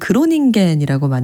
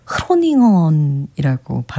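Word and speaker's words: Groningen: 흔히 저희는 그로닝겐이라고 많이 소개를 했는데 네덜란드어라서 흐로닝언이라고 발음한다고 해요.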